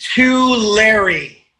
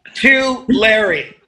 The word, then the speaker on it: Tulare: It's pronounced Tulare. – It's Tulare. – It's if you hand something to a guy named Larry, Tulare.